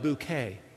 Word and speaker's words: Bucket: So she pronounced it not bucket, but bouquet.